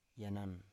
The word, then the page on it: yanan: Biak